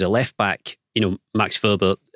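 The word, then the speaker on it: Wöber: And we listened to a pronunciation of it, and it seems to be Vuber.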